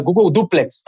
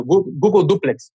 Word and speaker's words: Google Duplex: que é um projeto chamado Google Duo, em que, ou melhor, Google Duplex, falei errado, Google Duplex,